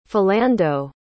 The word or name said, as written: Philando